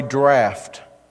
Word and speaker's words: draught: draught. Which really is the proper way to say that, but it's certainly not the way it's spelled, right?